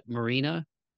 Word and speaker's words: Marina: I feel like I'm dece with pronunciations, but Marina. – Yeah. Yeah, Marina's correct. It's a Russian name.